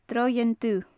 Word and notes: droigheann dubh: gaelic

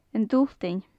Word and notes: an t-Sultain: gaelic

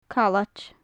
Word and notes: callaid: gaelic

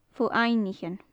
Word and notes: fo-aibhnichean: gaelic

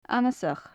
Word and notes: annasach: gaelic

